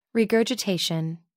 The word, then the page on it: regurgitation: British English